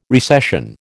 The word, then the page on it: recession: American English